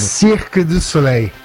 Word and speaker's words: Cirque du Soleil: Por favor, como é que, qual que é a pronúncia correta em francês, você que esteve lá durante muito tempo? Fala pertinho do microfone. – Cirque du Soleil.